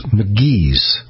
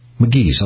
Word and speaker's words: McKee's: It's McGee's 37. McGee's, I'll spell that. M-C-K-E-E-S.